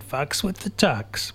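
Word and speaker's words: Fux With The Tux: Fucks With The Tux. And I didn't say a rude word. It's spelled F-U-X.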